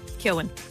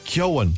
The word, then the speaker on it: Keoghan: Barry Keoghan or Barry Keoghan? – Keoghan. – Keoghan. – I'd say Keoghan.